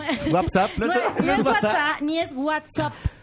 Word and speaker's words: WhatsApp: de esa herramienta, se tiene que pronunciar WhatsApp.